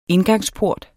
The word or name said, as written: indgangsport